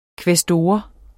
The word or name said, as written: kvæstor